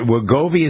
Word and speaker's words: Wegovy: So there's information about is it Wegovy? Is that how it's pronounced? – Yes.